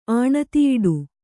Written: ಆಣತಿಯಿಡು

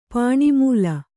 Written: ಪಾಣಿಮೂಲ

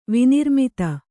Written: ವಿನಿರ್ಮಿತ

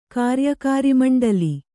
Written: ಕಾರ್ಯಕಾರಿಮಂಡಲಿ

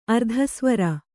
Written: ಅರ್ಧಸ್ವರ

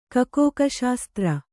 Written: ಕೊಕ್ಕೋಕಶಾಸ್ತ್ರ

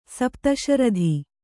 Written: ಸಪ್ತಶ್ರುತಿ

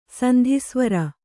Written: ಸಂಧಿಸ್ವರ

